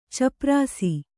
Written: ಛಪ್ಪರಿಸು

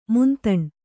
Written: ಮಂತಣ

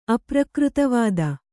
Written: ಅಪ್ರಕೃತವಾದ

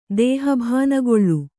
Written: ದೇಹಭಾನಗೊಳ್ಳು

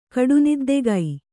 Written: ಕಡುನಿದ್ದೆಗೈ

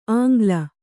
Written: ಆಂಗ್ಲ

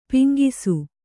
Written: ಪೆಂಗುಸು

